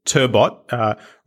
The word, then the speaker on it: Turbot: So you're the founder and CEO of a company called Turbot. Rhymes with robot, but spelt like the fish.